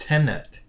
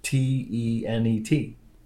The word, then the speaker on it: Tenet: Now, is the new movie Chris Nolan movie called Tenet or Tenant? What is how? What is it called? – Tenant, not tenant. You're adding another n in there. It's just Tenet. T e n e t. Tenet.